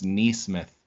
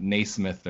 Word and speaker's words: Nesmith: So if someone goes a little early, I wouldn't be surprised if it's Nesmith. And I'm surprised his last name is pronounced Nesmith and not Naismith or Nesmith.